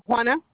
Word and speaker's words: Juana: Juana. – Spell that for me. – J-U-A-N-A. – What's your last name? – Espinoza, E-S-P-I-N-O-Z-A.